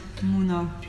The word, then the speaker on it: Mouna: The proper spelling is M O U N A. So different than English, but that's how we pronounce it. Muna.